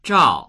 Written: zhao4

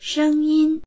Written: sheng1yin1

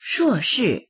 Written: shuo4shi4

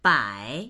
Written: bai3

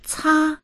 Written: ca1

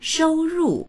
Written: shou1ru4

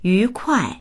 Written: yu2kuai4

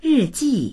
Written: ri4ji4

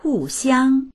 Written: hu4xiang1

